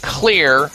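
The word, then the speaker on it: Xlear: And three, take something called Clear Rescue Nasal Spray. You can get this at health food stores and other big box stores. Clear, spelled with an X, X-L-E-A-R, Rescue Nasal Spray.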